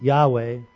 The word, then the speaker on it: Yahweh: So you will find when you read and study about this that Yahweh, Y-A-H-W-E-H, is the accepted pronunciation and spelling of God's name.